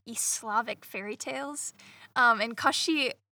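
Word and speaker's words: Koschei: I've found ways to pronounce it, so Gaucher. We'll both probably say 10 different... Koshi. There's several, and they're all pretty cool and also Russian. So... – Gaucher. It's Gaucher.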